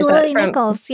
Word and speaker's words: Lily Nichols: I love your episode with, do you say her name? Lily Nichols? – Mm-hmm, Lily Nichols.